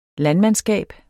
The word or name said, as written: landmandskab